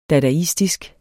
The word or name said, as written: dadaistisk